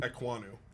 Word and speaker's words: Ekwonu: You take um, Owo, uh, I can't say his name. Oh, oh, Akeem Ekwonu. – Ekwonu, thank you, Ekwonu.